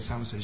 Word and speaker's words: Samoset: Somerset? Is that right pronunciation? Somerset. S-A-M-O-S-E-T. Okay, Somerset.